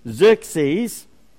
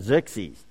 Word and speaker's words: Xerxes: Xerxes, the pronunciation is Z-E-R-K-S, Z-E-E, that's how you say it, Xerxes, X-E-R-X-E-S.